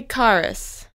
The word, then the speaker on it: Karas: Julie Karas. That's spelled K-A-R-A-S. – Julie, K-A-R-A-S.